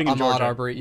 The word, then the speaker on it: Ahmaud Arbery: The reason why that was on my lips was just because it was like I was watching the Ahmaud Arbery. Is that you say his name? – Ahmaud Arbery. Yeah. Yeah.